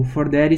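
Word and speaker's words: 4Daddy: O Fordery se escreve 4 numeral D-A-D-D-Y.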